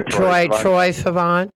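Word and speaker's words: Troye Sivan: And speaking of like young stuff, I know one of the projects that you work with, how do you say his name is Trey Sivan? Is that how you, is that his name? – Oh, Troy. Yeah. – Troy Sivan.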